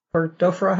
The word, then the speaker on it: burgdorferi: So let's talk a little bit about how Lyme, and let's talk about the classic Lyme first, the Borrelia burgdorferi. How do you say it? – Burgdorferi. Yep.